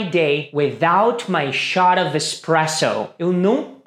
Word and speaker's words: espresso: Algumas pessoas nos Estados Unidos costumam colocar um X aí na pronúncia falando espresso. Mas a pronúncia mesmo é espresso.